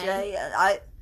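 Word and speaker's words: Shai: And then there's Shen. – Shay.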